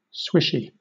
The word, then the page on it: swishy: British English